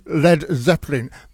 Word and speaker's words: Led Zeppelin: thus inspiring the final name change to Led Zeppelin, the lead being spelled L-E-D to make sure people pronounce the name correctly.